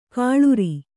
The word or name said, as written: ಕೊಳ್ಳಾರ